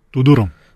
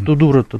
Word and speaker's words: Тюдором: она после этого каким-то образом вот стакнулась с, со своим пажом Оуэном Тюдором. Тюдором? Да. Ну, Тудура-то туда, да. это валийское валийский. произношение.